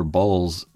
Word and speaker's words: Bawls: Anyone remember Balls? B-A-W-L-S.